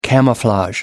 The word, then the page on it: camouflage: British English